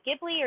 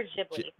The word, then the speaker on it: Ghibli: Is it Ghibli or Ghibli? – I think you can pronounce it both ways.